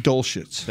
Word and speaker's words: Dulcich: Dulcich.